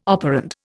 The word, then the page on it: operand: Indian